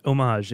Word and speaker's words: homage: It is homage if you're staying with the French pronunciation.